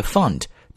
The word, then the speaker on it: fund: For example, he is the founder of the company, and he managed to get the fund to support his company. Fund 是 F-U-N-D, found 是 F-O-U-N-D. So there's a very slight difference in these two words pronunciation.